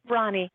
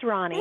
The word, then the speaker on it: Ronie: It is spelled R-O-N-I-E, and it is pronounced, tell us. – Ronnie. It's Ronnie.